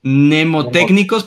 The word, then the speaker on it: mnemotécnicos: Mnemotécnicos, pero se escribe MNE, es como mnemotécnicos.